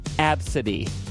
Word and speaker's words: Abcde: A, B, C, D, E. That's the name. But it is pronounced Absidy. People will come up with that, whatever they think.